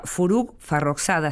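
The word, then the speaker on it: Forugh Farrokhzad: En este caso vamos a hablar de alguien que en su momento tuvo fama, se llamaba Furuk farroxada así se pronuncia lo más aproximadamente posible al farsi.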